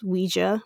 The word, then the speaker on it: Ouija: So Charles Kennard stated that he named the new board Ouija, pronounced Ouija.